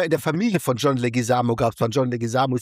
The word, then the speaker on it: Leguizamo: Okay, wie falsch habe ich es ausgesprochen? – Ich glaube, er heißt Leguizamo. Also von daher war es nicht so schlecht.